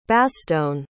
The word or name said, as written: bastogne